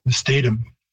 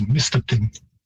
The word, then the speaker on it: Mistatim: The spelling, make a mistake on this, but I know there's a town in Saskatchewan called, I believe people pronounce it Mistatum. And that's spelled exactly like we would spell it in Cree, but the word is Mistatim.